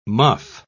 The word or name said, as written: muff